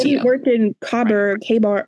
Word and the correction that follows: Khyber: How do you say that one, Catherine? – I think Khyber. – Khyber. That makes more sense. That would not work in Khyber.